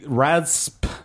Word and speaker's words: raspberry: There should be no P in there. – Agreed. – It should just be R-A-Z and berry. – Yeah, it should be raspberry. – No one says rasp.